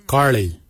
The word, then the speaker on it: giilyi: Darb is spelled D-H-A-A-B. It is a traditional knife, a darb. For boomerang, we say karli. Karli is the traditional name for boomerang. Karli is spelled G-I-I-L-Y-I, karli.